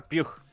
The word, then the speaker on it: Pugh: Ah, se pronuncia Pugh.